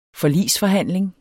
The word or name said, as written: forligsforhandling